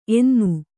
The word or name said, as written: ಎನ್ನ